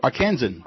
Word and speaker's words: Arkansan: Am I saying that right, Bob? – Arkansan. – Arkansan. Thank you.